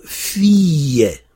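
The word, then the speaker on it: fiye (فيّ): C'est comme ce qu'on a vu avec « ila », donc ça se prononcera « fiye ».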